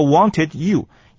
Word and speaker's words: wanted you: 第三个那个 you 呢，哈，跟 wanted 连接在一起的时候啊，那个 wanted you。有的时候呢，那个 D 不太发音啊，把 U 念的完整，而那个 D 呢就啊牺牲掉，因此念作是 I just wanted you, I just wanted you to shut up.